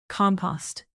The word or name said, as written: compost